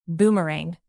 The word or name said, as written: boomerang